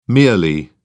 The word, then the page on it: merely: British English